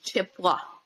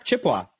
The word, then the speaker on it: Chippewa: I know they do a lot of drop ins at CrossFit Chippewa. Did I say that right? – Yeah, you said it like an outside. It's just Chippewa. – Chippewa.